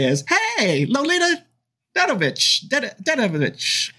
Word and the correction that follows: Davidovich: And then you pronounce it right, and you say Davidovich. – Davidovich. I've never pronounced it right, but hey, it's her.